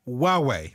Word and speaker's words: Huawei: And this week it's a flagship Chinese smartphone pronounced Huawei.